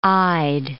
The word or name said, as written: I'd